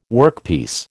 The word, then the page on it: workpiece: American English